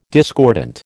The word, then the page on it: discordant: American English